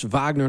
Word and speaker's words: Wagner: Okay, so let's, and I take it it's pronounced Wagner, not Wagner, thank you. – That's the Russian way of doing it.